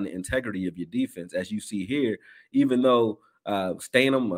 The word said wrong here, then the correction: Stidham: or I don't know how to say his name either, Stidham. That quarterback from Stidham, there you go, isn't a running quarterback.